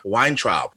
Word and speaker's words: Weintraub: Lauren Weintraub.